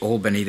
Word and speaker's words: Albany: And they pronounce it Albany there, not Albany as we do.